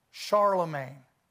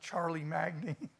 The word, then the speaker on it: Charlemagne: Alexander, Caesar, Hannibal, Charlemagne. I gotta say, Charlie Magni. When I was young, when I first encountered Charlemagne in school, I pronounced it Charlie Magni.